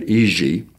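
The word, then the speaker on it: Jiří: His name is J-I-R-I, which they pronounce not Jiri, but Iji.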